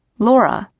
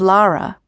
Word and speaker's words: Laura: Laura, he pronounced it, instead of Lara.